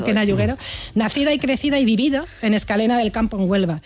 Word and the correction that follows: Escacena: Escacena. Mira que te lo he dicho mal varias veces, ¿eh? Por esta, Escacena.